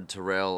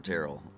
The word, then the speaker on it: Terrell: which it's either Terrell or Terrell, whichever one.